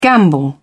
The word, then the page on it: gamble: British English